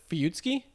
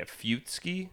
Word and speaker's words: Fuyutsuki: And before I fuck it up anymore, how do you say his name? Give me the full syllabic breakdown so I'm not... – Fuyutsuki? – Yeah, Fuyutsuki. – Kozo Fuyutsuki is the whole thing, but Fuyutsuki.